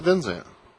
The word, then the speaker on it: Vinzant: Brett Vinzant. What? – Because it's like Bertrand and Bertrand. It's like Vinzant, Van Zant.